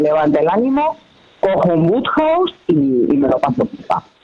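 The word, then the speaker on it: Wodehouse: El autor es Pelham Grenby Woodhouse, obviamente inglés, y está editado por Anagrama.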